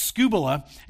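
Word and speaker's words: scubula (σκύβαλα): It's a Greek word. It's pronounced scubula.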